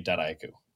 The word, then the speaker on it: DataIQ: So that's the biggest is in the US, you tend to hear DataIQ as sort of four different syllables. And the French tend to say it DataIQ, since that's okay. – How they pronounce data. So I will say I've probably heard 100 different pronunciations of it over the years and it all works, but I always personally say DataIQ.